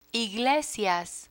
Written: iglesias

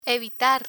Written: evitar

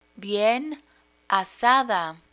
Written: bien asada

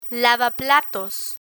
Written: lavaplatos

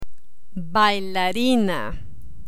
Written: bailarina

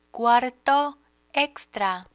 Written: cuarto extra

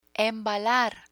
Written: embalar

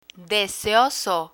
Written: deseoso

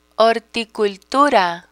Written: horticultura

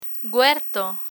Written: huerto

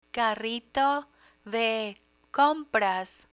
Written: carrito de compras

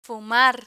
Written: fumar

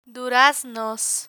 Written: duraznos

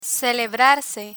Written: celebrarse

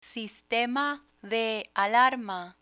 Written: sistema de alarma

